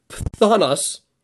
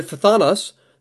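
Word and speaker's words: phthonos: And just to get super technical here, the Greek word, I have no idea how to pronounce this, it's P-H-T-H-O-N-O-S, so pthonos, that's the word there for jealously.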